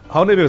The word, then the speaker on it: Honey: Oney se pronuncia en inglés, pues.